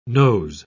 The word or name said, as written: noes